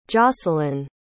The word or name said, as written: jocelyne